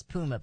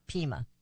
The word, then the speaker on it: Pima: I thought it was Puma, but it's Pima.